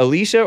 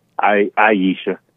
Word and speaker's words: Aisha: is it Alicia or Aisha? – Aisha.